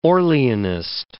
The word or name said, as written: Orleanist